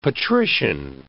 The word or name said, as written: patrician